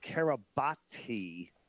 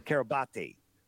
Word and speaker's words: Kiribati: And it was registered to the island of Kiribati, which was it's spelled out Kiribati with a T. Kiribati, right? And so where is Kiribati?